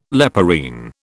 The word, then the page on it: leporine: British English